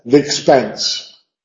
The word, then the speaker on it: the expense: The expense, sí, hay síncope. The expense. Suena un poco ridículo, pero the expense. Son dos sílabas.